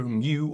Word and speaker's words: mu: It's the simplest formula there is, and tells us that frictional force, F, equals the Greek letter mu or mu, different people pronounce it differently.